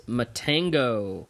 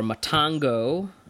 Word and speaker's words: Matango: Um, and we are talking about um, Ishiro Honda's Matango or Matango. Um, I've heard it pronounced both ways.